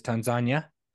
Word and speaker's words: Tanzania: When we flew to Amsterdam and then we landed at the Kilimanjaro airport, and then we actually drove a buttload in Tanzania, which is how they say it. So Tanzania. – Well, it's not Tanzania, it's Tanzania.